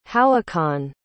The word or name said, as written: howahkan